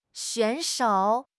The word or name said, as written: 选手